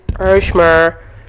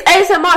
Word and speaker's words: ASMR: That's not pronounced ASMR. – It's ASMR. It's an acronym.